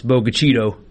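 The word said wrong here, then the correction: Bogue Chitto: Oh, man. – Chitto, man.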